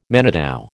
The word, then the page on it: manitou: American English